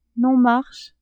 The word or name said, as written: non-marche